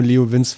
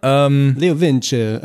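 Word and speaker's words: LeoVince: Ist das Leo Vinci oder habe ich das mein Leben lang immer nur Leo Vinz falsch ausgesprochen? – Keine Ahnung. – Das sind Italiener.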